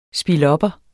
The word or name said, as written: spilopper